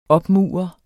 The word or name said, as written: opmure